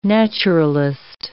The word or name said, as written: naturalist